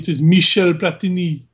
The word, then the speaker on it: Michel Platini: Well, now, really quick, just so we get that pronunciation right, remember, he is French. It is Michel Platini.